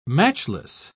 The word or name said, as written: matchless